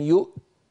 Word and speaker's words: يؤت: يؤت فننطق همزة ساكنة ثم تاء شديدة ثم مهموسة، ولم يؤت، هكذا نقف على هذه الكلمة.